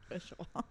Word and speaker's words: Au Cheval: But it's Au Cheval. – Yeah. – And I was like, people pronounce it how they want to pronounce it. But then I was like, well, the correct way is Au Cheval.